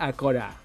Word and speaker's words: Acora: La pronúncia acurada seria a corar. – La pronúncia acurada seria a corar, perquè és arribar al cor, tant en el sentit de matar com en el sentit d'emocionar.